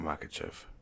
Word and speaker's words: Makhachev: Is that how you pronounce it? Makhachev. Yeah. Islam Makhachev. – Sounds about right.